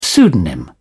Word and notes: pseudonym: British English

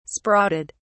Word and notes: sprouted: British English

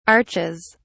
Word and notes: arches: British English

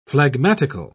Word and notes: phlegmatical: British English

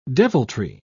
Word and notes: deviltry: British English

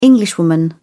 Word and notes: englishwoman: British English

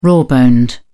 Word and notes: raw boned: British English

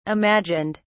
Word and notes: imagined: British English

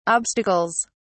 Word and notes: obstacles: British English

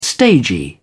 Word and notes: stagy: British English

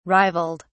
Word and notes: rivaled: British English

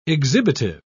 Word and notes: exhibitive: British English

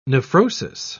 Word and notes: nephrosis: British English

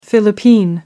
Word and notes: philippine: American English

